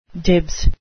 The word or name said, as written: dibs